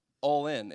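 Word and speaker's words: Ollin: So there's this Aztec word, since I'm very fluent in Aztecian, and the word is all in. It's O L L I N, not A L L I N, but it's O L L I N. It's pronounced all in.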